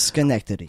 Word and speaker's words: Schenectady: It is Schenectady.